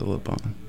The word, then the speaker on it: Le Bon: I think it's pronounced Le Bon. – She's not French. – Is she? – She's Welsh. – Where is she from, though? Like, where was she born? – Wales, I think. – Oh, she's... Okay. – But I think she pronounces it Le Bon.